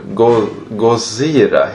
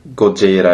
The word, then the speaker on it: Gojira: Och eh, just namnet är ju då Go Gozira heter det, uttalas det tydligen. Okej. Okay. Eh, och just ordet Gojira eller Gozira, hur man nu vill säga det.